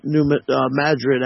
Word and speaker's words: New Madrid: They were going to harp the New Madrid, actually, as it's pronounced.